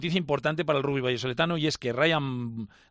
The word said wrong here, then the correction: Riyad Bachar: que siempre se lo lo digo mal, Riyad Bachar fue nombrado nuevo presidente de El Salvador, como ya se sabía, sustituyendo a Santi Toca.